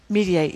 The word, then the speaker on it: Mediaite: How's that how you pronounce it? – Mediate, yeah, they're very, I think so.